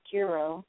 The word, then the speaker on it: Kuro: Look, Kuro burger, which Kuro, or must be pronounced Kuro, K U R O, means black in Japan, um, Japanese.